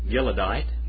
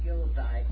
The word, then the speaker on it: Gileadite: The Gileadite. – Gileadite, and let them be among those who eat at your table.